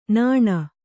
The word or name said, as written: ನಾನು